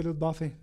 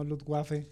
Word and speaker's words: Luftwaffe: nada más como comentario se dice Luftwaffe, no Luftwaffe, porque no son waffles de comida.